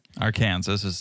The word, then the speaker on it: Arkansas: It was Liz's idea because Small Ed has a lot of family in Arkansas, that's right.